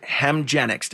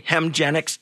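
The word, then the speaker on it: Hemgenix: Canada's just delivered its first publicly funded Hemgenics. Did I say that right? – Yes. – Okay, Hemgenics infusion.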